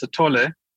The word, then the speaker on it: Sithole: How do you pronounce his name? – Thanks for the intro there, Thomas. That sounded very good. With this guy, his pronunciation is Moses Sithole, and he's definitely one of the, I guess, as you call it, you always say the rock stars of serial killers.